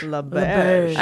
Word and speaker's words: LaBerge: Uh, we're going to do Exploring the World of Lucid Dreaming by Stephen LeBlanc. Berge. LaBerge. I just uh, Lauren was so nice.